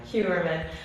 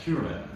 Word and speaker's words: Heuermann: Heuermann. Rex Heuermann. Heuermann.